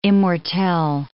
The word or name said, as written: immortelle